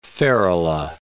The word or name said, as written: ferula